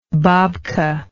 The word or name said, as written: babka